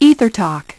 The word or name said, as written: ethertalk